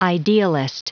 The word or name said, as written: idealist